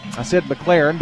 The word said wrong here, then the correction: McLaurin: but it's McLaurin. I knew that. Just said it wrong there.